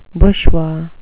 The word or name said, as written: bushwa